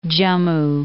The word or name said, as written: Jammu